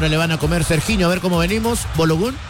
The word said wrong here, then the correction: Balogun: Fularín Balogun.